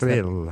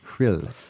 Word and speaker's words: Rhyl: What's Rhyl. – That? Rhyl. How do you spell that? – R-H-Y-L.